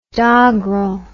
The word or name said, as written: doggrel